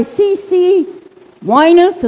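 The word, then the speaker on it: CeCe Winans: Goodness by C.C. Winus.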